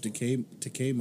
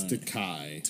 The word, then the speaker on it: Takei: It's a George Takei moment there. Oh my. – No, it's Takei. – Takei. – Takei. – Oh my. – Honor the man by saying his name correctly.